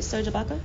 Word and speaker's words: Serge Ibaka: But yeah, so Serge Ibaka and, did I say it right? Serge Ibaka? – It is Serge.